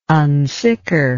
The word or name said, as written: unsicker